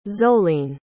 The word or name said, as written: zoleen